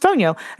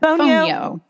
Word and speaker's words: Fonio: Fonio. Pronounced like the telephone, phone, and then like the yo-yo, yo, Fonio.